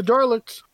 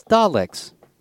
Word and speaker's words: Dalek: You're putting an R in there, buddy, that doesn't belong. – It gets a bit of an R sound in there that throws, you know, for pronunciation purposes, there is a slight R sound. – I think Ian was just assuming that they're using the British emphasis to drop off the R. We are Daleks. – Yeah, there's no R in the name spelling, but there is an R pronunciation in there.